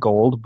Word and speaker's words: GGOOLLDD: Oh, Gold, which is, I'm going to say it Gold, but it's spelled out G-G-O-O-L-L-D-D.